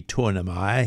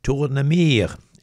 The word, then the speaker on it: Tournemire: Of course, he's French, so he can't be Tournemire. He's Tournemire, and he was born in Bordeaux in 1870.